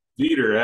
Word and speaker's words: Veeder: Am I saying your last name correctly? Is that right? – Veeder, actually. – Veeder, so yes. – A long E in the front.